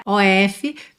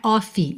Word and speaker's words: of: OF, pintadinhos de verde aí, só para te lembrar que a pronúncia não é off, e sim of, of. Nem o O tem som de O, nem o F tem som de F, tá? O O tem o som de A, e o F tem som de V, of, of, alright?